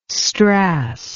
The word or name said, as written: strass